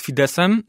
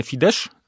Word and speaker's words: Fidesz: Tak, tak, dokładnie, ale już z Fideszem jest inaczej, bo w Polsce dość często czyta się go, i to jest błędne, Fidesz, mm-hmm. Z, a czyta się Fidesz bez Z.